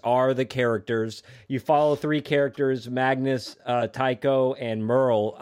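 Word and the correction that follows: Taako: I think I pronounced Taco. – Taco.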